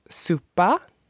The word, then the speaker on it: súpa: Soup is pronounced súpa.